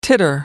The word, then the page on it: titter: American English